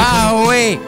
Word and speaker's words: Bowie: David Bowie, come si pronuncia? – Bowie.